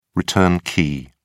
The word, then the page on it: return key: British English